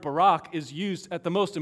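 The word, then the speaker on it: Barak (בָּרַךְ): In the Hebrew, the word sounds just like our president's first name, Barak. That's, that's how the, the Hebrew word is pronounced, Barak.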